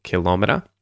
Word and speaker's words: kilometer: But yes, a kilometer, which is how I would say it, so stress on the second syllable, kilometer, is a metric unit of measurement equal to 1,000 meters or approximately 0.62 miles.